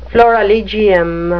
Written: florilegium

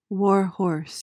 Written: warhorse

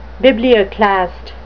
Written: biblioclast

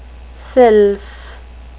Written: sylph

